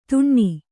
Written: ತುಣ್ಣಿ

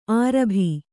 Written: ಅರಬ್ಬಿ

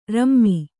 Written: ರುಮೆ

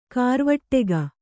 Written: ಘರವಟ್ಟಿಗೆ

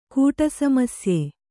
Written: ಕೂಟಸಮಸ್ಯೆ

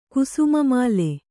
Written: ಕುಸುಮಮಾಲೆ

